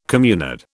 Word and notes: communard: British English

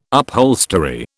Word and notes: upholstery: British English

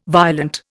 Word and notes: violent: Indian

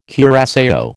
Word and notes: curaçao: American English

